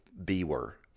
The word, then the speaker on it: Biewer: And the name is Biewer. And it's pronounced like beaver. It's a Biewer.